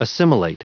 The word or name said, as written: assimilate